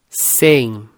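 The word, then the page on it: 100: Brazilian Portuguese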